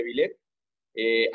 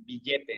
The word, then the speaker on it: Vilett: Eh, antes teníamos el mismo nombre, pero se pronunciaba, lo pronunciaban billete en la región. Este, y decidimos darle por ahí un cambio, un giro, precisamente para que no sonara, no nos dijeran, nos ubicaran como billete.